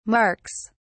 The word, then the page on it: marks: British English